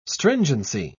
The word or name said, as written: stringency